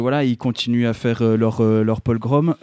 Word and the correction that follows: pogrom: voilà, c'est comme ça que ça a été qualifié par, par ces gens. que J'ai mal prononcé. Je pense. Vas-y. – Hein, leur pogrom Pogrom, pardon. J'ai rajouté un petit L.